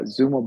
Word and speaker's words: Xumo: And it's been really exciting that we entered into this joint venture with Comcast with this product called Xumo, X-U-M-O, pronounced Xumo.